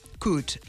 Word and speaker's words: could: I l'altre són algunes lletres mudes que no s'han de pronunciar, com, per exemple, would, could, la L, que nosaltres la veiem, no?, en la grafia, però no s'ha de dir.